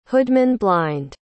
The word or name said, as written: hoodman-blind